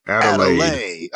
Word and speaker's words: Adelaide: did I say that right? – Adelaide. – I always think I've said Adelaide. – It wrong. – Adelaide. Like Kool-Aid.